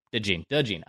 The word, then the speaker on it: DeJean: And with that 15th overall selection, they take Iowa cornerback Cooper DeJean. Is it DeJean? Is that how you pronounce his, his last name? Did I have it right? – DeGene out of Iowa.